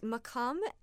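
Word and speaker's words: maqam: Kanun, K-A-N-U-N. And the scale, I saw the spelling of this recently. It's M-A-Q-A-M. – Yeah, right? Yeah, it's makam. Often in Turkish, it's spelled with a K, M-A-K-A-M, but it's the modal system.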